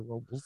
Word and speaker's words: Robles: Yeah, it is, because like correctly, it's Robles. – Robles. – But Paso of course, Robles? All the white locals, it's Robles.